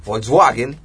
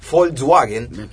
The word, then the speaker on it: Volkswagen: Estas son autopartes que van a fábricas de autos, por ejemplo, Volkswagen. Volkswagen. – Qué bien que los pronuncie. – Sí, pues estuve de intercambio 2 años en Alemania.